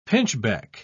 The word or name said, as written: pinchbeck